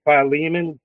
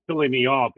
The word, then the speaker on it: Philemon: And we need, the importance of being connected is in Philemon, which I used to call it filling me off because I didn't know how to pronounce it yet.